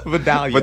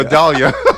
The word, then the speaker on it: Vidalia: These are, what is it, Valdea? How do you pronounce them? – Vidalia. – Vidalia. – Yeah, Vidalia. That is Vidalia onion.